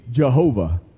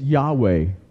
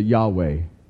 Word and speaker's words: Yahweh: At one point, we thought the English pronunciation would be Jehovah. So you've heard that name. That's this name for God. And lately, they're thinking, no, that's not how you pronounce it. It's Yahweh, maybe. Yahweh.